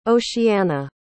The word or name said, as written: oceana